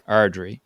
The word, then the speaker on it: Ardrey: Robert Ardrey, if I'm pronouncing that correctly. – Ardrey.